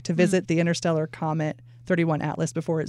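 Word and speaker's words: 3I/ATLAS: Is it 31 like dash Atlas, or did you just say 31 Atlas? – 31 Atlas.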